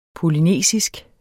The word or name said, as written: polynesisk